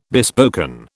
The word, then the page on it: bespoken: British English